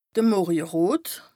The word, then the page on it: de Morjerot: Bas-Rhin; Strasbourg